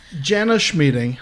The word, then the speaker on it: Jana Schmieding: Jana Schmieding, how you doing? – I'm great.